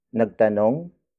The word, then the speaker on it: nagtanong: For example, nagtanong ako kay Eva. Nagtanong ako kay Eva. Nagtanong ako kay Eva. It means, I asked a question to Eva. Word for word, it's nagtanong ako kay Eva. Nagtanong ako kay Eva.